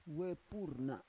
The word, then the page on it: we pur na: Roon